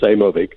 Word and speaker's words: Aimovig: It's not on my list of Yeah, I think you got it pretty close. It's Aimovig.